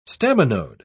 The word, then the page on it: staminode: British English